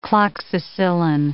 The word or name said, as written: cloxacillin